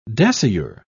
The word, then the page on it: dasyure: British English